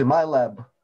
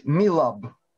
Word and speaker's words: MILAB: Так вот, десятки лет в той же Америке существует э, программа, которая называется, если читать ее в транскрипции, MyLab, а правильно говорить MILAB, Military Abduction, военное похищение,